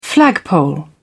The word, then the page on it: flagpole: British English